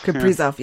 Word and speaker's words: Kaprizov: Yeah, I don't know how to say his name. Kaprizov? – Kaprizov, yeah.